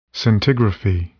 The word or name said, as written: scintigraphy